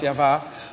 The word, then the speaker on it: Yahweh: So it says, we are his workmanship, created in Mashiach Yeshua unto good works, which Yahweh, and we use that a Hebrew tetragrammaton, yod heh vav heh, which we pronounce Yavah, hath ordained that we should what?